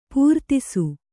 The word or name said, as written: ಪರದೇಸಿ